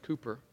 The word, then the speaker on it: Cowper: Now, it's spelled Cowper, but it's pronounced Cooper.